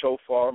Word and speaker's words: Shophar: So, again, it's Shofar. My name is spelled Shophar. That's P as in Paul in the middle, Shop as in Paul, Har.